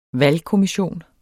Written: valgkommission

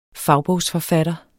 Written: fagbogsforfatter

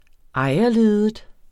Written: ejerledet

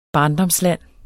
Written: barndomsland

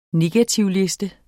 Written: negativliste

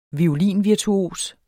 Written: violinvirtuos

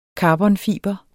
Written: karbonfiber

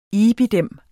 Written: IBID

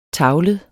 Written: tavlet

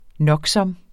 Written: noksom

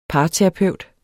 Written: parterapeut

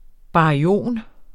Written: baryon

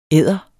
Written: EDDER

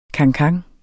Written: cancan

